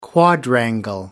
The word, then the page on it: quadrangle: British English